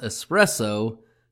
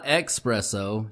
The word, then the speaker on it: espresso: Tonight I am drinking a frigid Underworld espresso, and it's pronounced espresso, not expresso, you morons.